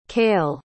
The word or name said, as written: cayle